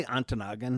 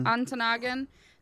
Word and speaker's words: Ontonagon: Is it A or A? I would say Ontonagon. – Ontonagon.